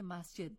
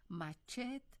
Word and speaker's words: مسجد: مثلا کلمه مسجد در ایران با همین شکل تلفظ می شود. در هرات و در لهجه غلیظ هراتی با آن مچت یا حتی مچت می گویند. در کابل ماجد میگویند و در هزارجات ماجید میگویند، ولی این یک کلمه است، دو کلمه مختلف نیست.